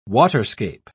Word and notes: waterscape: British English

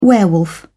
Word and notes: werewolf: British English

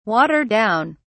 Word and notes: water down: British English

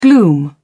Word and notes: gloom: British English

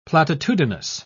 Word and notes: platitudinous: British English

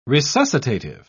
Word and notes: resuscitative: British English

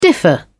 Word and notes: differ: British English

